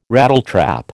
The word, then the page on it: Rattletrap: American English